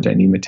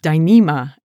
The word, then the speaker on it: Dyneema: And then hopefully next year I can have a Dyneema tent.